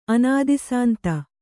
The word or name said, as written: ಅನಾದಿಸಾಂತ